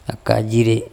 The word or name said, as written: akajire